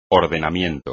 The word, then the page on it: ordenamiento: espagnol